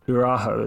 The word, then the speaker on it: Araújo: See, the Google pronunciation says Araujo, Araujo is how you pronounce it.